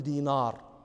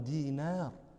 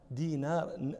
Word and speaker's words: دينار: النون رقيقة، النون حرف رقيق، لا نقول دينار، هذا نطق عجمي، نحن عرب نقول عبد الله بن دينار. دينار نون، هذا حرف رقيق أعرابي، يعني لتفهموا وتدركوا هذا الذي كانوا يحسه الأعراب في ذلك الزمن.